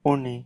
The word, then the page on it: uni: Lwaitel